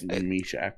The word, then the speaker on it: Mysak: We have DNVR Bets, which we will follow up at 6 with another prospect watch-along with Jan Mishak. That's probably not how you say his name, I'm going to be honest with you. – It's close. Mishak.